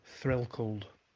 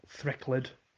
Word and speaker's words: Threlkeld: Threlkeld is how we'd say it, not Threckled or Threkled or anything like that.